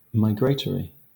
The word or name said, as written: migratory